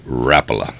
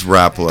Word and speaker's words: Rapala: It is pronounced Rapala. – It is Rapala.